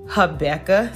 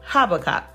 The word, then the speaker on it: Habakkuk: All right, the first one is, some people pronounce this Habakkuk, some people say Habakkuk. However you pronounce it, it comes from Habakkuk 2:2.